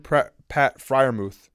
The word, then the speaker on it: Freiermuth: And I will give Steelers tight end Pat Freiermuth.